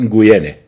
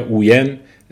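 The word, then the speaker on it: Nguyen: فرد دیگه توی وی نگوین، اون فامیلش رو خیلی سخت تلفظش، فقط میدونم که میگن 30% مردم ویتنام فامیلشون نگوینه، که حالا اون نگوین یا اوین تلفظ میشه.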